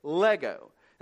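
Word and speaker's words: lego (λέγω): Saying comes from the Greek word, and kids, you'll like this, lego, and that's exactly how you say it, lego.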